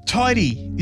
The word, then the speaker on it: Tydi: Tidy is a Brisbane DJ and producer. It's spelled T-Y-D-I with a capital T, capital D.